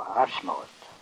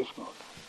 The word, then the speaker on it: Esmered: Ja, det är uttalat bara Asmaret. – Jaså? – Det uttalas inte Esmaret. Asmaret ska du säga. Det heter Asmaret.